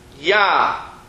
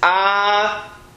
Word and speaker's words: Adonai: Now, that next word, literally, if you would want to read it incorrectly, is yah, right? But that is not how we pronounce that. You can see the way it's pronounced is all the way on the top of this page where it says in very large fonts next to the words the Lord is Adonai.